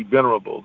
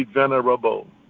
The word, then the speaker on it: Venerable: The 8th District has 8 states, and his name has 4 syllables. And I delight in pronouncing each one. Brother Edward G. Venerable, God bless you.